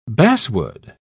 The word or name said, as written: basswood